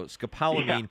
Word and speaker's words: scopolamine: So scopolamine is the right pronunciation of it,